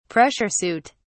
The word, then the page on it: pressure suit: British English